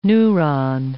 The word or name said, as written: neuron